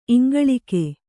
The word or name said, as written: ಇಂಗುಳೀಕ